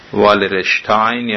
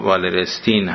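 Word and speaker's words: Wallerstein: نظریه نظام جهانی از امانوئل والرشتاین یا والرستین هست، تلفظ آمریکایی و فرانسوی اسم ایشون هست.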